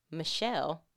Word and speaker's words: Michal: When David returned to home to bless his own family, Michelle, but it's spelled like Michal, the daughter of Saul came out to meet him.